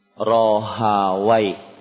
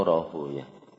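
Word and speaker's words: Rahawaih: Ishaq bin Rohawai. Hah? Rohawai. Atau dibaca Rohu ya. Ya, ini cara membaca. Dua-duanya dipakai. Dua-duanya dipakai. Mardawai atau Marduyah, Rohawai atau Rohuyah.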